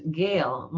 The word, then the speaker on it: Gale: Uh, one thing to, to end, which is Gale. My last name is spelled G A L E, like the storm, just in case you're looking for the book, which I hope you are.